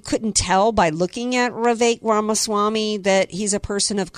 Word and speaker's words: Vivek Ramaswamy: Anyway, I think it was Thursday or Friday when he had Vivek Ramaswamy on, I can never pronounce it. It's Vivek Ramaswamy on his show, and Ramaswamy gave it to him.